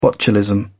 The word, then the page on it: botulism: British English